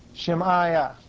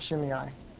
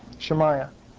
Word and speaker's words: Shemaiah: Shemaiah, not Shimei. Excuse me on the pronunciation. Shemaiah.